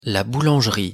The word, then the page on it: la boulangerie: French